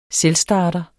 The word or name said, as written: selvstarter